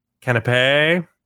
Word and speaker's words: canapé: There was canapes. How do you pronounce that? – Canapé.